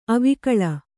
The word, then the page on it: ಅವಿಕಲ: Kannada